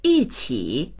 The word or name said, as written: yi4qi3